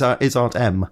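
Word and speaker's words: Em: Although it's spelled E-M, but, you know, but when you hear it, it's Aunt M.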